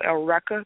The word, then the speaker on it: Eureka: And Zammed was made by the same team as Eureka 7, or if you want to get technical, Eureka, that's how you say her name. But, like, Zammed, which is spelled X-A-M-D, it's a pretty complicated story.